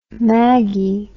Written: maggi

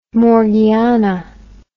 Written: morgiana

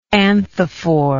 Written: anthophore

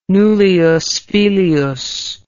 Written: nullius filius

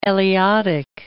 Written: Eliotic

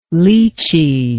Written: leechee

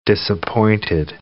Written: disappointed

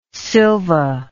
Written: sylva

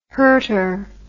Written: hurter

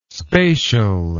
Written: spatial

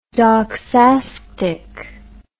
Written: doxastic

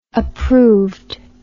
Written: approved

